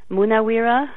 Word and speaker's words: Munaweera: So there's this lovely Sri Lankan author named Naomi Munawira, and I'm going to spell that. It's Munaweera. Naomi Munawira,